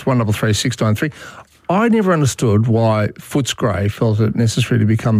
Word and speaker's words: Footscray: Pronounce it properly. It's Footscray. – Footscray.